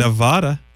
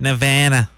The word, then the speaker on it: Nevada: Nevada. – No. That's incorrect. Nevada. Nevada. That's that's more correct. Yes, you got to say it like that.